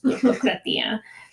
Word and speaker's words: Turkokratia: Just free from the Turks / Ottomans, or what is known as the Turkokratia. Sorry, I always like to add syllables to things. Turkokratia.